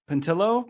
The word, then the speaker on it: Pontillo: We got James Pontillo. – Pontillo. – All right, yeah, man, how's it going? – You know, you already know to pronounce the L's.